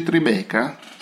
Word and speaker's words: Tribeca: No, una domanda, si legge Tribeca? – Sì, Tribeca. Qua dicono Tribeca.